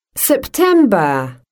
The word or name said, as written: September